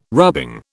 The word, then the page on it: rubbing: British English